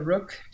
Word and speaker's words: Rokh: It's spelled R-O-K-H, but it is pronounced Rokh.